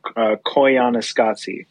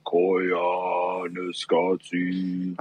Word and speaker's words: Koyaanisqatsi: Imagine that your throat is a natural didgeridoo, and you just go, Koyaanisqatsi.